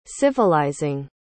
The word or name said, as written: civilizing